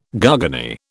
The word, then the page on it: garganey: British English